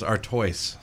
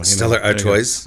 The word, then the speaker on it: Stella Artois: I was thinking it was Artois. So Stella Artois.